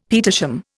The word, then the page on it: petersham: Indian